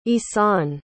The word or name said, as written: ihsan